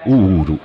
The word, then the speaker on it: Uhuru: e quindi appunto abbiamo scelto Uhuru in lingua Swahili, okay, vuol dire libertà. – Ora si sa come si dice, non sbaglieremo, per favore. – No, non pronunciamolo all'inglese, no. È semplicemente Uhuru.